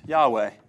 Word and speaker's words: Yahweh: As far as we can tell, the best Hebrew pronunciation of that is Yahweh. Now, I'm throwing in vowels, which in the Hebrew text, they weren't there. Okay? It's just Yod, Hey, Vav, Hey. Just four consonants.